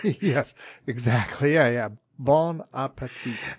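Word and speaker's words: bon appétit: Well, what everybody says and what they ought to be saying is bon appetit. No T pronounced at the end.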